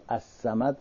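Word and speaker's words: الصمد: همونطوری که السمد لامش تلفظ نمیشه، به گوش کسی هم نمیاد، درک به حواس نمیشه و کسی نمیشنوه.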